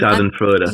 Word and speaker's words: Schadenfreude: And, like, is that Shutter Freuden? Or I've never known how to pronounce that word properly. Like, I'm Schadenfreude.